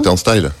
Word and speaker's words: Turnstile: Comment, comment on le prononce Turn, Turnstile ah, Turnstile. Turnstile.